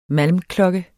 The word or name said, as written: malmklokke